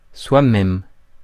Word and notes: soi-même: France; [swa.mɛm]

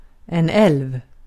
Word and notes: älv: /ˈɛlv/

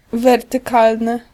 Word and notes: wertykalny: [ˌvɛrtɨˈkalnɨ]

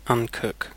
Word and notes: uncook: British English

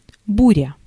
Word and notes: tempête: France (Paris); [yn ˈtɒ̃.pɛt]

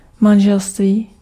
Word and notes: huwelijk: /ˈɦywələk/

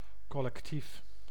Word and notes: groupe: [ɡʁup]; France

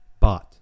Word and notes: bot: American English

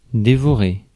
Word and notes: consume: English

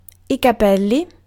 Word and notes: capelli: /ka.ˈpel.li/

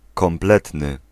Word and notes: complet: France; [kɔ̃.plɛ]; French

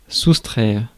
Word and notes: soustraire: France (Paris); /sus.tʁɛʁ/

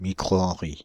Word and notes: microhenry: France (Île-de-France); /mi.kʁo.ɑ̃.ʁi/

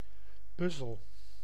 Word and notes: puzzle: /ˈpʌz.əl/

